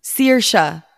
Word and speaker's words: Saoirse: For Iris, I was thinking, I think you pronounce it Sasha Ronan. – Oh, Saoirse you know? Ronan? – Saoirse, yes.